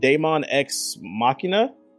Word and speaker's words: Daemon X Machina: I don't know if I'm pronouncing this right. Daemon X Machina. Is that how you pronounce it? – I think so. – I think you got it good.